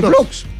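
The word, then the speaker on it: Blockx: Porque se escribe B-L-O, B larga, ¿no? Sí. B alta. L-O-C-K-X. – Z. – No. No, no, no, ¿eh? Se cierra con X. – Entonces Blocks nomás. – Es Blocks, pues.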